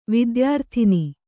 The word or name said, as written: ವಿದ್ಯಾರಾಧನೆ